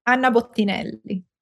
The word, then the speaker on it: Anna Bottinelli: Anna Bottinelli.